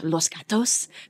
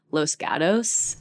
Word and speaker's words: Los Gatos: Well, my town that I grew up in is called, if you said it in Spanish, Los Gatos, but we all say it like white people because it's in California, and we say Los Gatos.